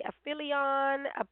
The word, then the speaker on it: aphelion: July 3rd is the aphelion. I think it's P-H, so it's pronounced with the F, aphelion. But you are, you know, you can look that up, A-P-H-E-L-I-O-N.